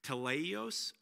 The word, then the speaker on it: Teleios: Okay, so the word they're using for perfect comes from, good luck with the pronunciation. Here it comes. Teleios, I think. T-E-L-E-I-O-S.